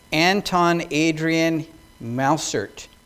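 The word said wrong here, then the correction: Mussert: Is that correct? No? What's the proper pronunciation? Mausert. Okay. That's my accent.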